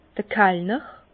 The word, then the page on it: d'r Kallner: Bas-Rhin; Herrlisheim